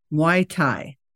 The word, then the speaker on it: Muay Thai: So let me first start by, before we kind of go into your background, we're going to be talking about Muay Thai.